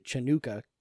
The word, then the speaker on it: Chanukah: And I also pronounce it Chanukah.